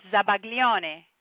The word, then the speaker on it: zabaglione: Sabayon is actually French word, and in Italian it's zabaglione, which is basically the same thing.